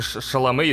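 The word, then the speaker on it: Chalamet: А почему не знает, как правильно произносить фамилию Тимати Шаломе? Почему? – Потому что правильная фамилия Тимати Шаламе, естественно, Тимати Шардане, потому что ну, он создан для Вообще...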